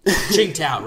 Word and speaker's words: Qingdao: Qingdao, right. – Qingdao. – Yeah, I'm still working on it. – But it's Mandarin. Not like, it's not spelled Qing. It's not C-H-I-N-G. It's T-S-I-N-G. But it's pronounced Qing.